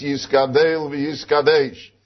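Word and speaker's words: Yiskadel v'Yiskadesh (יתגדל ויתקדש): And therefore he says the correct pronunciation is Yiskadel v'Yiskadesh, because in the Novi Yecheskel it says v'Yiskadilti v'Yiskadishti. It doesn't say v'Yiskadalti v'Yiskadashti.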